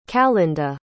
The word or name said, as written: calynda